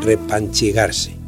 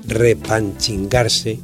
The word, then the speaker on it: repanchigarse: Lo mismo ocurre con su versión repanchigarse, incorrectamente reinterpretada como repanchingarse y rempanchingarse.